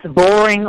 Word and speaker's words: Boring: I presume that's how it's pronounced, Boring, a fully green funeral home. And she's on the board of directors of the for the North American Natural Burial and on the advisory board of the Green Burial Council. So it's a pleasure to welcome to today's show. Welcome. Glad you're with us. – I'm thrilled, and yes, Boring, Oregon. You said it exactly how it is.